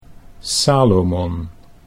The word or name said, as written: Salomon